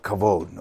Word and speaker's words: kavod: The first one is the Hebrew word kavod, K-A-B-O-D, but you pronounce it with kind of a V, like kavod, okay?